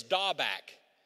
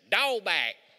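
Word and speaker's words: Dabak (דבק): The Hebrew word is dabak. I'm going to say it right because this is actually how you pronounce it. Dabak. Tell me Jesus didn't know that Southeast was going to be alive when this book was written. Dabak. It's spelled D-A-B-A-Q.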